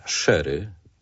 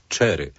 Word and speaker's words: sherry: Ja tutaj zwracam uwagę na wymowę, bo niektórzy będą myśleć, że sherry to jest wiśniówka. Absolutnie nie chodzi o wiśniówkę, bo tam mamy literę C z przodu i wtedy musielibyśmy mówić cherry.